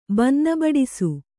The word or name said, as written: ಬನ್ನಬಡಿಸು